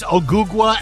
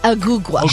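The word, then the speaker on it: Ogugua: I can't say his name. – No, it's Ogugwa Anunobi Jr. Ogugwa.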